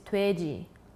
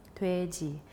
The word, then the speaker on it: 돼지: And so the Korean word for pork, for pig, is 돼지, 돼지. And it kind of rhymes with 예지.